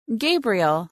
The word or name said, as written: Gabriel